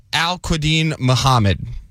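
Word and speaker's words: Al-Qudeen Muhammed: That is Al-Quadeen Muhammad. – Al-Quadeen Muhammad. A-L-Q-U-D... E-E-N. – Oh, you can just ding him now. – M-U-H-A-M-M-E-D.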